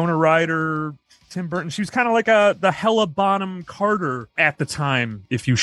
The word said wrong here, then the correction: Helena Bonham Carter: shall, because now all he uses Helena Bonham Carter.